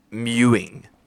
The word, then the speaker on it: mewing: Have you heard of this thing called the mooing? – What? – Sorry, I think I pronounced it wrong. It's mewing. – Mew, like M-E-W-I-N-G.